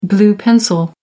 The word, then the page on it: blue-pencil: American English